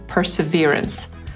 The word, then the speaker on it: Perseverance: Po brytyjsku przeczytamy to jako perseverance, a po amerykańsku perseverance. co z tą nazwą po polsku? Najlepiej chyba byłoby to czytać jako Perseverance. To najbardziej podobne do poprawnej angielskiej wymowy.